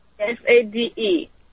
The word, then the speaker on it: Sade: Is that S A D I? Am I saying that right, Sadi? – S A D E. – S A D E. Welcome to the Dead Doctors Don't Lie program, Sade.